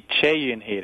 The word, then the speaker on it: Choi Eun-hee: And would that be Choi Eun-hee's, how do you pronounce her name, Choi Eun-hee? – It's actually Choi Eun-hee. There's something in Korean where the name Choi, which is written Choi, is pronounced Che.